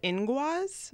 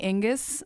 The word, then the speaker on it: Inguz: It's called Inguaz. – Inguaz. – So it's spelled I-N-G-U-Z. – Okay. – Yeah. – I was totally saying Ingus.